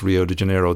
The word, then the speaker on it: Rio de Janeiro: And maybe because I have a few words of Portuguese, I should try and pronounce Rio de Janeiro the way the Brazilians pronounce it.